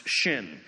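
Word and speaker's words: shin (שׁ): If there's a dot over the left branch, it is pronounced sin. If it is over the right branch, it is pronounced shin.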